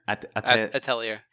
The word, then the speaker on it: Atelier: I don't know how to pronounce the first word. Atelier. – Atelier.